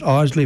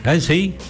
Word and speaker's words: Isley: Ronald Isley, and you can pronounce it Isley if you want. If it depends on what part of the world you come from. – Isley.